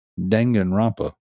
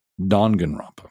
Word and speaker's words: Danganronpa: And the proper pronunciation is Danganronpa, not Danganronpa, in case you guys were wondering.